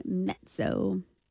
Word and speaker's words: Mezzo: Mezzo. – I think, right, in Italian, the double Z is always pronounced as a T, like pizza.